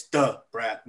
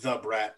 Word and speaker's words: Da Brat: I think, you got to say it right. It's Da Brat, not The Brat, like some white people would say.